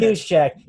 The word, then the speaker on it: Juszczyk: Juszczyk, by the way, but go ahead. – Juszczyk.